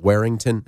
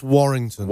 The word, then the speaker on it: Warrington: And Viola Beach is a band out of, I always say it wrong, Warrington? – It's Warrington.